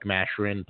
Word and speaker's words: Mascherin: One of their pick, their second round pick, Adam Mascherin? I don't know how to pronounce that. – Mascherin.